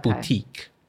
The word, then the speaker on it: boutique: boutique. It's the the stress on mm. the e, right? – Mm.